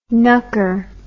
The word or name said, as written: knucker